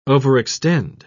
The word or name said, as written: overextend